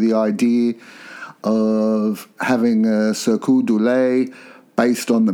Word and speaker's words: Cirque du Soleil: So in the late 90s, George Harrison met the founder of Cirque du Soleil.